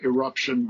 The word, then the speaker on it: irruption: Just like eruption, but it's with an I instead of an E.